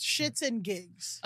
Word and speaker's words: SHXTNGIGS: And it's a podcast called, it's spelled SHXTNGIGS, but it's Shits and Gigs.